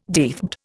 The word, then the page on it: deathbed: Indian